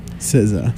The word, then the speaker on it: SZA: But we also heard a lot of songs from Zha. Is how you say it? – SZA.